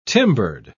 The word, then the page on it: timbered: British English